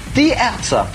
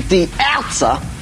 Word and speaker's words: Die Ärzte: Die Ärzte. Or pronounced properly, Die Ärzte. – Yeah. Or pronounced even more properly, Die Ärzte.